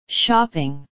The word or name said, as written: shopping